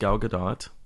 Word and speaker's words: Gal Gadot: Did you know that it's actually pronounced Gal Gadot? – I'm a terrible Jew.